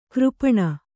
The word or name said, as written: ಕರಪಾಣಿ